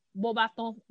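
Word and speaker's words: Beauxbatons: Pra falar a verdade, até acho que o Jake pronuncia em inglês, Bobatons. Eu prefiro Bobaton, que é o francês.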